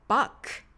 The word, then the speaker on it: Bach: バッハさんのお名前は英語ではバックと最後 K で発音されます。ネイティブの方の発音を聞いてみましょう。 My favorite composer is Johann Sebastian Bach.